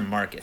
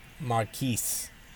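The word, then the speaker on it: Marquese: Is it Marquise or Marcus? How do you pronounce it even? – Marquise.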